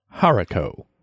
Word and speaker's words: haricot: And can you pronounce it for me in a proper fashion one more time? – Haricot.